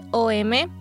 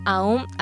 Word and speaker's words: Om: Realmente se escribe OM, pero se pronuncia como AUM, AUM. La pronunciadas juntas suena como la A y la U suena como una O, y la M pues es la M que suena como Om.